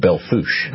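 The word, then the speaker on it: Belle Fourche: I believe it's pronounced Belfouche. – Belfouche, that's what I was going to ask you.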